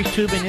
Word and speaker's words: Toobin: That Jeffrey Toobin is an analyst, as a matter of fact. That's the Sephardic pronunciation, Mr. Producer.